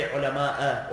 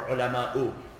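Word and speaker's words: العلماءُ: Now what's the proper way to pronounce it? إِنَّمَا يَخْشَى اللَّهَ. Is it مِنْ عِبَادِهِ الْعُلَمَاءَ or ulama'u? Ulama'u.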